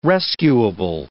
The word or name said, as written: rescuable